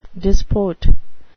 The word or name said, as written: disport